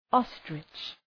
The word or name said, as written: ostrich